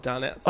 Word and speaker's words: Donettes: The brand that I get is called Donuts. – Oh, it's called... Okay, I thought you were just drastically mispronouncing donuts. – No. They're D-O-N-E-T-T-E-S.